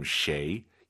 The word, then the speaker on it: 谁: 쉐이, 쉐이 해도 알아듣지만 정확한 발음 쉐이, 입을 가로로 쫙 찢으면서 쉐이 발음하시기 바랍니다.